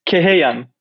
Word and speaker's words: Kehyeyan: It's Kehyeyan, yeah.